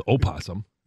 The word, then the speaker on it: opossum: Yeah, they put that O in front there. – Yeah, exactly. Well, nobody says opossum. – The silent O? – Those are the ones from Ireland.